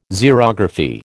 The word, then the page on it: Xerography: American English